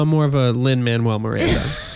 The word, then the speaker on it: Lin-Manuel Miranda: I'm like, is it Miranda Lin Manuel? Lin-Manuel Miranda. It's Lin-Manuel Miranda.